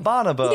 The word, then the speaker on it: Bonobo: I don't know if I'm pronouncing that right. – Yeah, I think that's how we'd say it. – Bonobo.